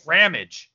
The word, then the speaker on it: Ramage: Ramage. Like damage.